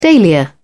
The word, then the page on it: dahlia: British English